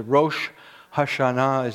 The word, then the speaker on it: Rosh Hashanah: But the unofficial name is the one that the Jewish people call it as Rosh Hashanah is the proper way of pronouncing it.